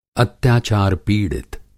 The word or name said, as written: अत्याचार पिड़ित